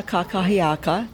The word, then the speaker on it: kakahiaka: Aloha kakia. Gotta pronounce it correctly. Aloha kakahiaka.